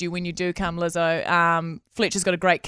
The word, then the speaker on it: Lizzo: How you say it? – Lizzo. – We love Lizzo.